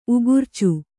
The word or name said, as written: ಉಗುರ್ಚು